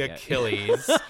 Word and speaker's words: Achilles: There was like something else that I pronounced, or I said, because like, what is the proper pronunciation? Like Achilles, but I said Achilles. It was very Pittsburgh of me. – Oh, I assumed it was like, well, maybe. – No, it's definitely Achilles.